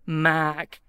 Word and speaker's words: Mark: So you got guys can say Mark, okay? Here I have to say Mac and sound like a sheep.